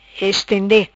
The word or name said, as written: Estende